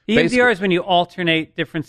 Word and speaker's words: EMDR: But talking to Dr. Drew, he suggested this EMDR therapy, which I used to call EDMR therapy, which was completely wrong. And then I started thinking about electronic dance music, quit being a fucking idiot, you're saying it wrong.